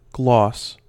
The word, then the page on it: gloss: American English